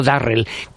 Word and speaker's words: Durrell: escrito con D de Dinamarca, los Durrell, pronunciado Darrell, que